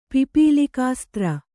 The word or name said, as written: ಪಿಪೀಲಿಕಾಸ್ತ್ರ